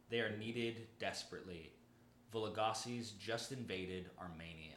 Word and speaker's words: Vologases: Vologases. – Yeah, Vologases, yeah. I think it's just it's the way I've always pronounced it.